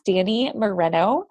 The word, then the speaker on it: Dani Moreno: Today we have with us Dani Moreno.